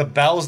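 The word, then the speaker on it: Bealls: So I went to, like, the Bells down there, which is spelled Beals, like Jessica Biel style. I think there's an extra L in it.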